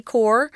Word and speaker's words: corps: So this is all thanks to Canine Therapy Corps. This word corps, the S is not pronounced on this word, guys, so be careful with that one.